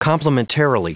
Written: complementarily

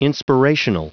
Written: inspirational

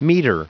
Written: metre

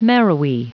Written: marrowy

